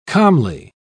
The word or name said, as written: calmly